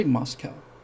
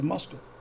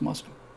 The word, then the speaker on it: Moscow: I don't say Moscow, I say Moscow. That's how it's pronounced, Moscow.